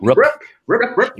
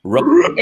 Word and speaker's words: Rupp: Rup. – Rup. – Galen Rupp. – Rupp.